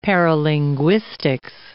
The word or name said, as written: paralinguistics